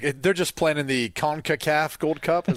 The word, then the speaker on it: CONCACAF: Yeah, CONCACAF.